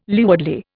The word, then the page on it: leewardly: Indian